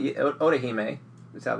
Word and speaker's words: Orihime: Orihime is how they pronounce Orihime.